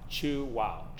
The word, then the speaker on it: Chuao: So, let's say Chuao the right way. How do you say it? Well, uh, we like to say that you chew and say wow. Chew wow.